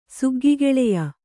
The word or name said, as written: ಸುಗ್ಗಿಗೆಳೆಯ